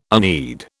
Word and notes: unread: British English

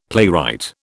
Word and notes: playwright: British English

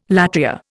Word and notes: latria: Indian

